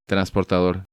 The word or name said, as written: Transportador